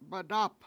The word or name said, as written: madAp